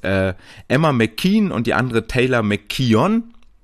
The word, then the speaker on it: McKeon: Nee, Kaylee McKeon, Entschuldigung, Kaylee McKeon, beide sehr ähnlich geschrieben, Emma McKean mit K-E-O-N und Kaylee McKeon, K-E-O-W-N. Auch hier mal schön die wirklich native australische Aussprache zu hören, dann kriegt man auch mal mit, dass dass die Emma, die 100 Delfin-Schwimmerin, eher McKean mit Doppel-I quasi gesprochen wird und McKeon noch so ein kleines O mit drin hat.